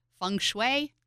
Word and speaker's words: feng shui: Feng Shui? Is that how you say it? – That sounds right.